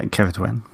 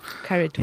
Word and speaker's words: Ceridwen: Yeah, Ceridwen. Yeah, Ceridwen. – Ceridwen.